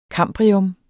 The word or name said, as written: kambrium